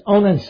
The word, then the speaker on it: Onan: John Calvin, he said he referred to birth control, he referred to it as murder and called Onan's sin, am I pronouncing that right? Yes, Onan's sin, doubly monstrous.